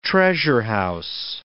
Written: treasure-house

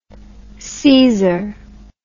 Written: seizor

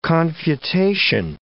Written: confutation

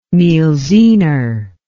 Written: mielziner

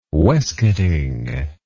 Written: waistcoating

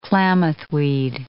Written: Klamath weed

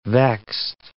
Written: vexed

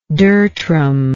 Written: dertrum

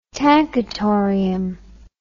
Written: tankatorium

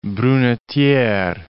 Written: Brunetiere

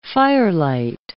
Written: firelight

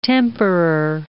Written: temperer

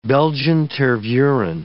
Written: Belgian Tervuren

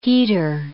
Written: heater

